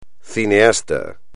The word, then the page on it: cineasta: espagnol